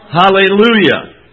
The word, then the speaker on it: Hallelujah: And the Hebrew is the same word, but it's got an H in front of it, and so we pronounce it what? Hallelujah.